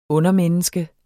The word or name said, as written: undermenneske